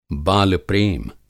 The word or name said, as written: बाल-प्रेम